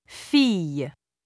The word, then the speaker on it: fille: ILL and ILLE are generally pronounced as y, famille, fille.